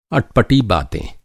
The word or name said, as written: अटपटा